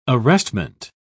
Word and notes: arrestment: British English

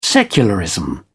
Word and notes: secularism: British English

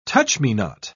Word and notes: touch-me-not: British English

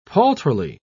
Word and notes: paltrily: British English

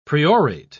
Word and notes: priorate: English; British English

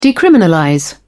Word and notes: decriminalize: British English

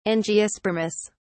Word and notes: angiospermous: British English

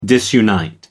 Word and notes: disunite: American English

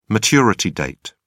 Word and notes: maturity date: British English

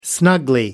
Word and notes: snugly: English; British English

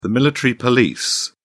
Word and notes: military police: British English

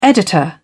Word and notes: editor: British English

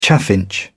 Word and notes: chaffinch: British English

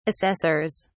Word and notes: assessors: British English

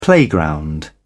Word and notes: playground: British English

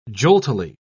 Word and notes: joltily: British English

